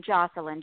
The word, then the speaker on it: Jocelyn: You pronounced my name right, but my there's only one N in Jocelyn, J-O-C-E-L-Y-N.